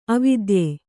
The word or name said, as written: ಅವಿದ್ಯೆ